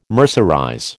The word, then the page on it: mercerize: American English